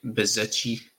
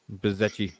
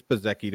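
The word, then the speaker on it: Bezzecchi: But they still can't get Bizetchi right. – Yeah. Bezecchi. It's Bezecchi to me.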